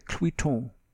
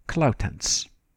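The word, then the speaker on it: André Cluytens: from the Requiem by Gabriel Fauré, Pie Jesu, sung by Victoria de Los Angeles, with Parisian forces under André Cluiton. That's how Frenchmen pronounced his name, I believe. Dutch speakers and Flemish speakers would say something more like, I think, Clautens.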